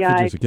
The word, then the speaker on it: Vujicic: The speaker that you're talking about is Nick, and I think his last name is pronounced Vujicic. – Oh, Nick Vujicic, yeah. – V-I-J-I-C-I-C, but I'm not the expert on that pronunciation there.